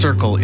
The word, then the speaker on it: CRCL: CRCL, pronounced Circle, is a mobile, community-led crisis response service in the Comox Valley.